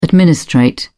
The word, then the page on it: administrate: British English